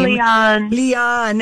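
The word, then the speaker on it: Liane: Now, we are fans of a fellow Australian author, Leanne or Lianne Moriarty. We can never remember how to say her first name. – Leanne.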